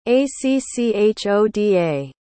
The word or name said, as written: acchoda